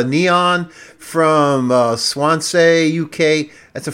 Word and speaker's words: Kneeon: And if you go searching for it on Bandcamp, it's spelled Neon. It's spelled K-N-E-E-O-N. So clever.